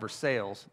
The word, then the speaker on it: Versailles: So then I got connected to this little church in a place called Versailles. That's how they say it.